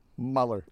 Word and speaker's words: Mueller: But apparently, if the man himself is saying, yeah, that's how you say his name, then I am down with Robert Mueller. – Mueller.